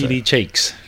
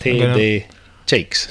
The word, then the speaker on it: T.D. Jakes: T.D. Jakes. – Just. – T.D. Jakes.